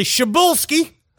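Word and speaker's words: Przybylski: It's actually Szybulski. – Oh, I guess P-R-Z-Y is pronounced Shuh.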